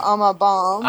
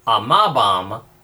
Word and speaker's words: amabam: Remember our Latin accent rule. If the penult, which is the next to the last syllable, when the penult is long, it is accented. If it is short, then the accent falls on one syllable back to the anti-penult. So, amabam.